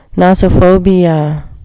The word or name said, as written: nosophobia